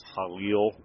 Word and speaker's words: halal: You have a lot of exposure now with the halal market, properly pronounced halal, okay, exposure.